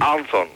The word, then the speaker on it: Anson: Anson. No, como suena, como lo leo, Anson, con acento en la S, Anson.